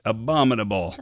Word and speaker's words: abominable: I read with my son, and every day we sat down to read it, I mispronounced it in a different way. All right, abdominal science. And he'd be like, no, Dad, abominable.